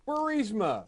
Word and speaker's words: Burisma: Could it be Burisma? – Yeah, I was going to say it rhymes with Shmurisma.